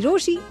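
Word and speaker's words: Rochie: मेरा नाम है मिर्ची रोशी, नाम की स्पेलिंग है RO CHII.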